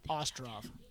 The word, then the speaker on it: Ostrov: Andy Ostrich. Do I always say it wrong? – You just have an accent, so it sounds weird. – What is it? – Ostrov. – Ostrov.